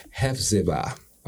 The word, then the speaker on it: Hephzibah: Her name is Hephzibah.